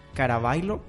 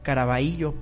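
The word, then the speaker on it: Carabayllo: Ah, se pronuncia Carabailo, Perú. Así se pronuncia. Carabaillo, Perú. Así es. Es mm.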